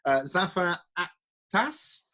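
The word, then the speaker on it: Zafer Aktaş: We are joined by head coach of Bahcesehir Koleji Super League team, Zafer Aktas.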